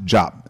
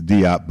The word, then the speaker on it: Diop: It's pronounced Jop, but it has been pronounced previously as Diop,